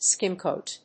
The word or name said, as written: skim coat